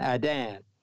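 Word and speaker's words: Iddan: Now, this word times in this text, 7:25, comes from the word Adan, Adan, and it is spelled I-D-D-A-N, I-D-D-A-N, Adan, and it carries the meaning of an appointed time or season as it relates to a festival day.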